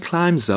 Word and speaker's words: climb: And climbs up the lion. And climbs up the lion. – Climbs up the lion. Climb. C-L-I-M-B. Remember, the B is not pronounced. It's just climb.